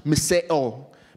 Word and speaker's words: miseo: We'll go into that in a while, but the word hate here in Greek, where the Gospel of Luke was originally written in, is pronounced miseo, miseo or miseo, and it means to detest.